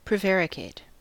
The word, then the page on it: prevaricate: American English